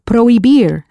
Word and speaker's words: prohibir: Prohibir, spelled P-R-O-H-I-B-I-R, that silent H again.